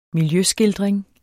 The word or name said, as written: miljøskildring